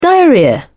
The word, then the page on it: diarrhoea: British English